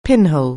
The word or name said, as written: pinhole